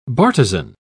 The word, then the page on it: bartizan: British English